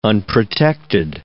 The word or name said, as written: unprotected